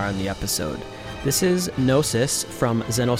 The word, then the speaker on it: Gnosis: So let's take a listen to, would you pronounce this Gnosis? – Yeah. – G-N-O-S-I-S. Gnosis from Zeno Saga Episode 1.